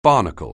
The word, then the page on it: barnacle: anglais